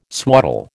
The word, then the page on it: swaddle: American English